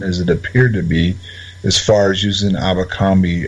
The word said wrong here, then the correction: Alcubierre: If I don't say it right, you can correct me. Drive Alcubierre.